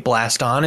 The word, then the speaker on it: Blaston: Uh, I think Blaston is great. Rhymes with Gaston for spelling.